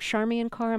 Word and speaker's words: Charmian Carr: And the female singer we'll hear, is her name Charmian Carr? Am I That's correct.